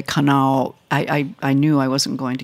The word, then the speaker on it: Kanawha: Yeah, Kanawha. – Kanawha, even though it's one syllable shorter than it looks.